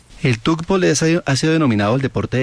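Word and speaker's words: tchoukball: Cuando tengas la oportunidad, el balón cuando rebota contra una malla, contra un marco, hace ese sonido como ch. Entonces por eso se pronuncia y se denomina así, tugbell.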